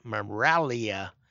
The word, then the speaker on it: Miraglia: Maybe Miraglia, I would pronounce in Italian, usually don't pronounce the hard G, so Miraglia.